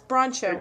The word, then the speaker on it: Broncho: See, it just looks like Bronco to me. I would never say it Broncho, but it's Broncho, whatever.